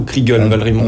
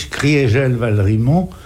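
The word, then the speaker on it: Kriegel-Valrimont: On prononce Criégel Valrimont, c'est bien ça, ou Kriegel Valrimont? – On prononce Maurice Criégel Valrimont pour marquer que en 18, l'Alsace a cessé d'être germanisée.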